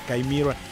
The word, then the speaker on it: Chimaira: Y solamente ellos sabían cómo se pronunciaba, entonces en una entrevista les preguntan que cómo se pronuncia su nombre y ellos dicen que se pronuncia Chimaira.